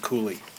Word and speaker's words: Cowley: So it's a poem called "On the Death of Mr. Crashaw" by Cooley, spelled Cowley but pronounced Cooley.